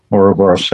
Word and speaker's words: Ouroboros: but that Ouroboros, is that correct pronunciation? – Yeah, Ouroboros.